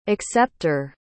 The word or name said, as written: excepter